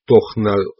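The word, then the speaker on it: Тональ: Я знал, что Таналь, произносится как Тохналь,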